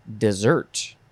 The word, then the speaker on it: desert: Third form, and this one is pronounced dessert. Dessert. This one is a noun. The verb form is going to be in the next episode. So this noun, pronounced dessert, is from the 13th century.